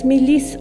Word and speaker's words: milis: Die Miliz wiederum muss türkisch als Milis ausgesprochen werden.